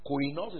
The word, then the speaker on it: koinos: Beloved, when I gave all diligence to write unto you of the same salvation, koinos soteria. Koinos is spelled K-O-I-N-O-S, koinos. It is from that word you have koinonia, is it clear?